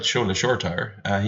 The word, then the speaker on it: Shola Shoretire: I'm told it's pronounced Shola Shoretire, which is spelled Shola Shoretire.